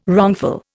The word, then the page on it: wrongful: Indian